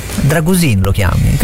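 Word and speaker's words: Drăgușin: Dragusin lo chiami, perché l'accento lì? – Ah, Dragusin, Dragusin. No? – Non lo sopporto già più.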